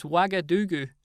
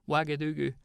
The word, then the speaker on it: Ouagadougou: I know how to pronounce it. It's Ouagadougou. My old geography teacher, Mr. West, used to ask us this on a weekly basis. What's the capital of Burkina Faso? Ouagadougou.